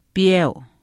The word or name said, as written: piyeu